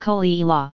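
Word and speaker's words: Caol Ila: Coal Isla, pronounced Coleila, was founded in 1846 by Hector Henderson and is located on the remote northeast coast of the island, close to Bunnagh Habhain.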